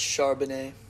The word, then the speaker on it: Charbonnet: I have Zach Carbonnet. Sorry if I said that wrong, but under 90.5 rushing yards. – I think it's Charbonnet.